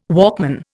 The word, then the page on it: Walkman: Indian